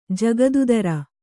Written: ಜಕಾತಿದಾರ

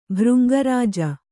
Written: ಭೃಂಗರಾಜ

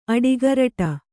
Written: ಅಡಿಗೊರಡು

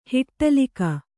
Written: ಹಿಟ್ಟಲೀಕ

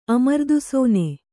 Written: ಅಮೃತಾಶನ

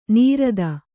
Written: ನಿರತ

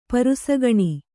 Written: ಪರುಸಗಣಿ

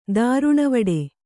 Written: ದಾರುಣವಡೆ

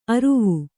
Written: ಅರುವೆ

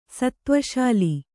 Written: ಸತ್ತ್ವಶಾಲಿ

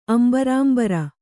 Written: ಅಂಬರಾಂಬರ

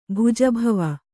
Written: ಭುಜಭವ

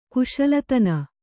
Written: ಕುಶಲತನ

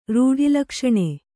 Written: ರೂಢಿಲಕ್ಷಣೆ

